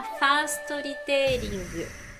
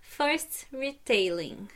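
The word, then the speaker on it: Fast Retailing: E em primeiro lugar, a empresa que mais contribuiu para que o índice Nikkei chegasse a 30 mil ienes foi a Fastoritering ou, falando numa pronúncia mais bonita, First Retailing.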